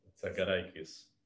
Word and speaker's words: Tzagarakis: Just tell me, how do you pronounce your last name? The T has to be silent. Because I don't want... – What is silent? – The T in your last name. No, it's... – Silent. Or you actually pronounce... – No. It's Za. Za. T-Z. – This is like Tzagarakis. – Yeah, it's Tzagarakis. – Tzagarakis. – Tzagarakis. Yes, really good.